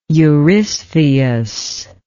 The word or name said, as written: eurystheus